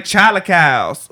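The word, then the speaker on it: chilaquiles: It's not spelled like Sheila, like that bitch that used to yell on bus 18 and punch people. It's spelled with a CH. – Oh, okay. – It's like Chalicals. – Chalicals. – Phonetically, it's spelled Chalicals.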